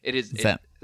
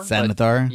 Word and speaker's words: Xanathar: He has information about the Xanthar Thieves Guild. – That was a really good pronunciation, I gotta say. It's Xanathar. – Xanathar.